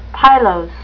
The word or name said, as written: pilose